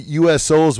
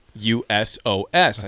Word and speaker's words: Usos: You said that the USOs would be fighting in tag team competition, and that would be the Usos. – How do you say it again? – Usos. – And you said the USOs.